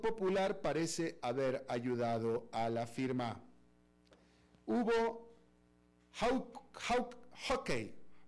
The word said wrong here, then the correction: Hawkeye: A ver, déjame se lo pronuncio bien. En realidad es Hawkeye, ojo de halcón.